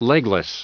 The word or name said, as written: legless